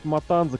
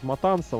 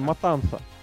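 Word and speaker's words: Matanza: И тот факт, что этот Матанза, кстати, правильно все это, наверное, говорить Матанса, он же там вот эта буква Z, которая в испанском читается как С.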